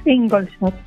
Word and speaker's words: Ingolstadt: Y por otro lado, nos vamos hasta Ingolstadt, Alemania, arquitecta de formación, fotógrafa de afición y vocal de FONAMAD. Buenos días. ¿Lo he pronunciado bien? – Buenos días. Sí, perfectamente. Ingolstadt.